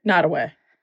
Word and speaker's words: Nottoway: Nottoway. Nod, like nod, head nod.